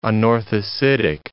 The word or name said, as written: anorthositic